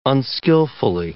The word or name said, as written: unskillfully